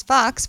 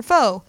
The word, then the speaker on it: faux: So I'm going to try and read this and not mispronounce Fox for faux.